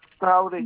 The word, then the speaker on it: Traoré: Traoré Yaye. – T-A-O-R-U. – E à la fin. – Donc, T-A-O-R-E. – Oui, c'est ça. Traoré. – D'accord. – Et Yaye, vous savez comment s'écrit Y-A-Y-E. – Y-A-Y-E.